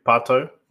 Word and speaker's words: Pato: Is that how you say his name? Is it just Pato? I can't remember. Alexandre Pato. – I know that. – Pato, yeah.